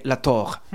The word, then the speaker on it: La Torre: Y Todd La Torre, aunque obviamente La Torre es español, pero ellos lo pronuncian Ted La Torre, ¿vale? – La Tog.